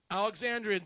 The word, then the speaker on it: Alexandrian: But it is in the Alexandrus. Am I pronouncing that right? Alexandrian, thank you.